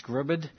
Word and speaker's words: Scribd: I've got Barnes & Noble, I think this is pronounced Scribd, Kobo is quite a big one as well, and they just distribute out via these online agencies.